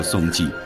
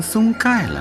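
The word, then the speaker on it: 松溉: 然而有一天，松济人发现他们世代居住的这个地方不叫松济，改名松盖了。因为新的字典上盖只有一个读音，为了保留家乡的古称，松济人计较了 13年，官司一直打到了重庆市人民政府。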